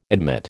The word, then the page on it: admit: American English